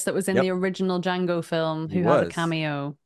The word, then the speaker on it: Django: And he goes, Django. The D is silent.